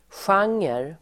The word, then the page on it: genre: [sj'ang:er]